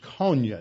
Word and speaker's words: Konya: Now, Iconian, if you pronounce the final consonant with an N instead of an M, which is typical as words go from one language to another, that M to N is a typical change. Iconium and Konya. You can hear the modern Turkish name Konya in the middle syllable of Iconium.